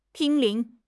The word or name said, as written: 濒临